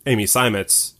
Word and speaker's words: Amy Simons: Especially because, like, you know, I feel like that probably supports Amy Simons more. Am I saying that right? – Yeah. – Okay. Yeah. Fuck yeah. Nice. – Pronunciation perfecto.